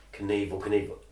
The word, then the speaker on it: Knievel: Like Robbie Knievel just sat there and went, Awful Knorfel, Knievel, rhymes with evil, I'll just change the spelling of evil, and that's how he came up with Evel Knievel.